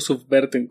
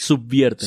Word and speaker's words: subvierten: ¿Subvierten o subverten? ¿Cómo se dice? – Subvierten.